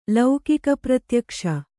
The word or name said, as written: ಲೌಕಿಕಪ್ರತ್ಯಕ್ಷ